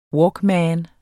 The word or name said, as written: walkman